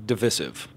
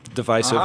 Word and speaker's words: divisive: We have two camps, divisive or divisive.